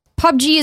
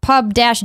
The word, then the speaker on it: PUBG: Says, hey, Greg, PUBG is pronounced Pub-G. – Oh my God. – Like the word pub and the letter G.